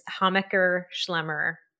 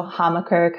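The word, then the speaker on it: Hammacher Schlemmer: It's a German name. So Hamaker, kind of a softer A, but it's also based in Chicago, where we love to pronounce our A's.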